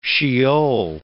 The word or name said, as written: Sheol